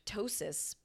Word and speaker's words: ptosis: There's partial ptosis, P-T-O-S-I-S. How do you say that when P and T are together? – Ptosis.